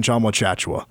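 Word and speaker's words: Tchamwa Tchatchoua: I was going to rattle off the name, but I was like, I got to quickly remind myself how to say it before I just spit it out. Don't want to look like a fool. Jonathan Chamwochachwa. That's right.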